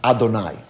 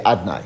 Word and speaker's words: Adonai: And so the part of God that expresses itself in wanting a distant relationship is the name Adonai. Or in Hasidus, you just say Adnai, because you don't want to pronounce it properly. Like you say Elohim, so you say Adnai.